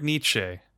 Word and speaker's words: Nietzsche: Frederick Nietzsche.